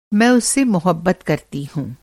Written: मोहब्बत

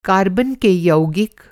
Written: कार्बन

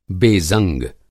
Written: बेज़ंग

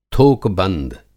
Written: थोक-बंद

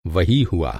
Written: वही

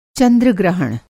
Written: चंद्रग्रहण

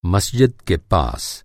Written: मस्जिद

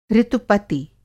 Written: ऋतुपति

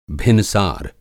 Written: भिनसार

